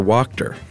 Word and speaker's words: Wachter: She worked in medical administration, and her boss at one point was named Dr. Wachter. W-A-C-H-T-E-R, pronounced Wachter.